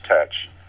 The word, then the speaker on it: Teach: And oh, coincidentally, TEACH in 1718 would have also been pronounced as tetch because the, the letters EA uh, during the transition from Middle English did not necessarily make a long vowel sound that we're familiar with today.